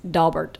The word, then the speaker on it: Daubert: And the main point I want to make is it's pronounced Daubert, not right. – Daubert.